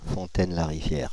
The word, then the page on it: Fontaine-la-Rivière: France (Île-de-France); [fɔ̃.tɛn.la.ʁi.vjɛʁ]